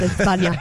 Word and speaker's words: España: You know it's actually pronounced España.